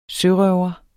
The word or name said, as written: sørøver